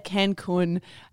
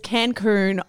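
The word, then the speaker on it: Cancún: Now that we're having a chat about mispronounced words on the podcast, people were very upset with how I said Cancun on the Scandal episode recently. Apparently I said Cancun. It's Cancun.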